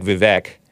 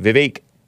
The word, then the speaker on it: Vivek: It looks like Vivek, but it's pronounced Vivek.